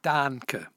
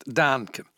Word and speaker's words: Dahnke: Dahnke, Horst Dahnke. Den Nachnamen schreibt man D-A-H-N-K-E.